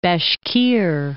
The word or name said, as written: Bashkir Republic